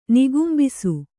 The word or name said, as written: ನಿಗುಂಬಿಸು